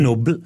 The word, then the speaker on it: Nobel: Jeg tror det uttales Nobel. – Er det Nobel? Ja, faktisk Nobel.